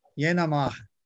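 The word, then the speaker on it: Y'en a marre: É, você me corrija se eu, se eu pronunciar errado, tá? Que era aquele movimento Yenamar, Yenamar, que é o nome do movimento. – Ah, Sim. – Eu pronunciei é certinho? Isso.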